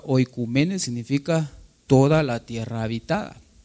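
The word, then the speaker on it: oikoumene: Aquí dice oikumene, O-U, pero O-U junto se pronuncia U, entonces es oikumene.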